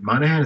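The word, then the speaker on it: Monaghan: I think I remember the actress's name because I know the actress, but the wife, is it Michelle, is it Monahan? Is how we pronounce her last name? – Yep.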